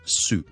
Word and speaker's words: soup: 一个汤同埋一个番碱发音嘅分别，一个汤咧就系 soup soup，而一个番碱系 soap soap。咁其实一个咧就系 oo 咁嘅音 soup soup soup，中间个 vowel 系 oo，呢个系汤啦。